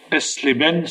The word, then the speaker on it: Eisleben: Luther est né en 1483 à Elsleben, c'est comme ça qu'on dit S-L-E-B-E-N. Oui, EISLEBEN. Oui, c'est ça, voilà. Bon, très bien. Vous avez la prononciation exacte.